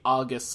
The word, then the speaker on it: August: You know, 95% of people say August Celestials because they see the word and they think the month, but apparently you're supposed to say August.